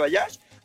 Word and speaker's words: Baja J: Y bueno, para que lo escriban bien es Baja J, así lo pueden escribir, pero se pronuncia Bayas.